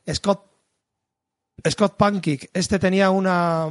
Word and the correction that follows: Panchik: Panchik. creo que es Panchik. creo que en inglés lo pronuncian Panchik. Está, estaba pensando cómo lo pronunciaba, pero creo que es Scott Panchik.